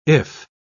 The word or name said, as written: iff